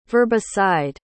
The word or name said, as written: verbicide